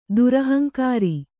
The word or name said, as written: ದುರಹಂಕಾರಿ